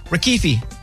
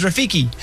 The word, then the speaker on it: Rafiki: Rafiki. Familiar? – Never heard of him. – What kind of animal is Rafiki? I said it wrong. Yeah, Rafiki. Yeah, that's why I said it again.